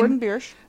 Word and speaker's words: Gordon Biersch: I actually just went to Gordon Biersch. I don't know how to say the name. – Yes, Gordon Biersch.